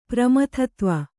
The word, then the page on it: ಪ್ರಮಥತ್ವ: Kannada